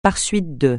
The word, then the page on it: par suite de: francais